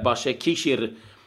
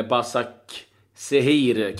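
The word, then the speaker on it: Başakşehir: Sen var det en, en otrolig tillställning mellan Klubb Brygge och eh, Istanbul eh, Başakişir, eller Basak Sehir kanske man ska uttala det.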